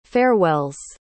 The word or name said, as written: farewells